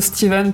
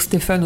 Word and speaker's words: Stephen: Alors, je prononce Stephen parce que c'est à l'américaine, voilà, mais on peut dire Stephen aussi, c'est pas très grave.